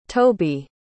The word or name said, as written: tobi